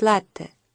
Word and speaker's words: латте: Странно, в России всегда ударение ставят на последнем слоге. А слово-то итальянское, и по-хорошему надо говорить «латте».